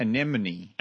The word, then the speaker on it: anemone: It's A-T-H-S. – It's asthma. Yes. – The frog princess hates when people say anemone. – Anemone. – It's an anemone. – Anemone, it is, yeah.